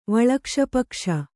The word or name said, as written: ವಳಕ್ಷಪಕ್ಷ